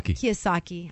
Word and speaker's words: Kiyosaki: Richard Kiyosaki.